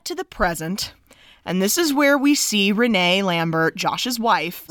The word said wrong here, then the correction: Renai: Renai. I'm sorry, guys. When we watched this film with the subtitles on, comprehending that that's how it's spelled, R-E-N-A-I, Renai.